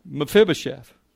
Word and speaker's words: Mephibosheth: His name was Mephibosheth. Everybody say that with me. That's hard, wasn't it? I've never seen anybody pronounce it right. Mephibosheth.